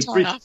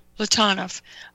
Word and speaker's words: Platonov: Platonov. Yeah, usually Platonov. Three syllables. The middle syllable is usually the one that's stressed. – Platonov.